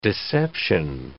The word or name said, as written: deception